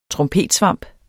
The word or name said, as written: trompetsvamp